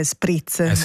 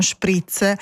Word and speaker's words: spritz: Il più diffuso, accettato in italiano standard, è spritz, detto proprio all'italiana. – Vero. – Se lo pronunciate alla tedesca, quindi vorrei uno spritz, è praticamente un calco esatto dal tedesco, forse un po' forzato, no Sergio?